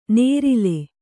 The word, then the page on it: ನೇರಿಲೆ: nērile